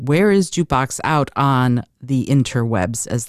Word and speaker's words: JKBX: So we pronounce it Jukebox, but it's spelled JKBX. So think like a stock ticker symbol.